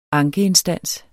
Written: ankeinstans

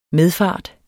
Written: MEDFARTENS

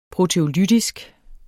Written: proteolytisk

